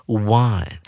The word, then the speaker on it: won: 而这边它使用的是它的过去分词 w o n，念成 one，也就是阿拉伯数字一的念法。